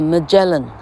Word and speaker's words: Magellan: 哦，呃，麦哲伦叫 Magellan 哈，Magellan 麦哲伦海峡吧哈，老客居然连麦哲伦怎么念都不会 Magellan 哈，Magellan，而且为什么说，啊，老客告诉 Angela Young 哈，移民纽西兰的，呃，台湾的小女生哈，啊，为什么是看着长片呢？